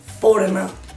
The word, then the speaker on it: foreigner: Somos los foreigner. – Foreigner. I'm a foreigner. Se escribe foreigner. Foreigner. – Sí, con una G ahí entre medio. – Sí, pero se dice. Foreigner.